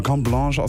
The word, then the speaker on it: Carte Blanche: Es wird Carte Blanche geschrieben, aber Carte Blanche ausgesprochen. Die Kernbotschaft von diesem Track ist Dankbarkeit.